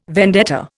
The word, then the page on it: vendetta: Indian